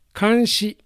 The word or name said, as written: Kanshi